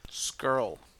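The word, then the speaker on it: Scurll: Squire out. – Skrull. – Squire. – S-C-U-R-L. – Squirrel. I'm never going to say it right, just to piss you off.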